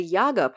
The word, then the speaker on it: Yaga: The Yaga part, which is pronounced with the accent on the second syllable, by the way, is harder to pin down.